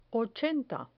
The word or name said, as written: ochenta